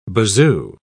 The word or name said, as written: bazoo